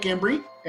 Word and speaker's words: Embry: Uh, tell us a little bit about your, your Mike Embry.